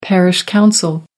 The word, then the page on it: parish council: American English